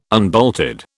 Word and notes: unbolted: British English

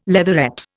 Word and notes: leatherette: Indian

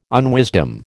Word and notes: unwisdom: American English

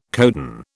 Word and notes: codon: British English

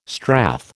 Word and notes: strath: American English

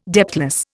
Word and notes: depthless: Indian

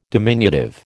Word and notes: diminutive: American English

